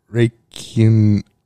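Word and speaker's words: Räikkönen: Raikkonen. Yeah, there you go. Raikkonen.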